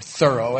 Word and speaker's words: Thoreau: Thoreau, or Thoreau as he's supposed to be pronounced, I understand, Thoreau.